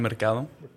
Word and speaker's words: Mercado: It's Juan Mercado. – Mercado. – Juan Mercado? – Yeah. – I'm saying that wrong. – It's fine. You just got to roll your R's a bit.